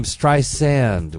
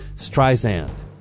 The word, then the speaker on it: Streisand: She pronounces her name Streisand with a soft S, like sand on the beach, not Streisand.